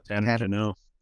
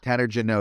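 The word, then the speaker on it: Tanner Jeannot: Is it Janot? – Oh, Tanner Janot. – Tanner Janot. Is that how it is? Okay. Yeah, Tanner Janot, who got a huge haul.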